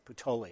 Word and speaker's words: Puteoli: And then they ended up in the Bay of Naples in Puteoli, is the way you pronounce that, Puteoli.